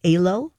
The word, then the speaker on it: Alo: Galleria already has a fitness brand coming in called, do you pronounce it Alo? – Yes. – Okay. – Alo. – Alo? – Mm-hmm.